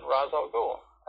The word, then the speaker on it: Ra's al Ghul: Ra's al Ghul, R-A apostrophe S, how's that pronounced? He said, Ra's al Ghul. I said, you mean it's not Ra's? He says, why would it be Ra's? That's stupid.